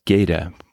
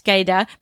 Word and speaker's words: Gaeta: He says Gaeta, but wouldn't you say Domaine Gaeta in French? – Yes, that's how I would pronounce it, Gaïda. But you know what? With that southern accent du midi, that's what probably most people would say, Gaïda.